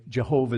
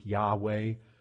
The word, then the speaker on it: Yahweh: Now I know the way scholars go today, Jehovah is not so much the accepted pronunciation of the tetragrammaton. Typically, people speak Yahweh, but there was a day when Jehovah Rapha, who knows what that means?